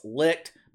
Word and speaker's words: Licht: David Zaslav reiterated the confidence that he has in the leadership and direction of Chris Licht, who, by the way, I know it's pronounced Chris Licht, but unlike the shit fucks, I refuse to say the word licked when I'm talking about a grown man.